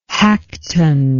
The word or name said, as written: hagueton